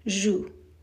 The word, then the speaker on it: joue: In French, khad, the singular, one cheek, is joue, J-O-U-E, J-O-U-E. In the plural, khudud, is the same pronunciation, joue, but it has an S at the end, J-O-U-E-S. The English spelling is J-O-U-E-S.